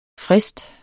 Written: frist

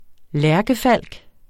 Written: lærkefalk

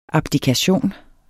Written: abdikation

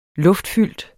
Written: luftfyldt

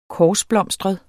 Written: korsblomstret

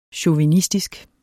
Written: chauvinistisk

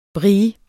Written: brie

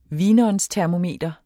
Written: vinåndstermometer

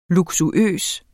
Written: luksuøs

